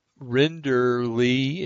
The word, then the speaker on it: Rinderle: And it's written by a blogger, Susanna Rinder, Rinderle. It's spelled R-I-N-D-E-R-L-E.